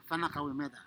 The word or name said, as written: Fana Kawiometa